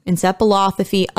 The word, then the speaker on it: encephalopathy: Encephalopathy, otherwise known as CTE.